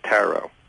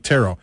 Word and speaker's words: tarot: but generally in dictionaries it says tarot. – All right, tarot. – But you could go tarot. It doesn't matter as long as you don't pronounce the final T.